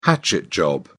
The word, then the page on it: hatchet job: British English